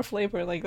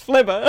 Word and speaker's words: Flubber: Or flavor, that's flubber. – Look at that, flubber. – Yeah, that flavor. – That's not flavor. Flubber. No, you're adding too many weird syllables.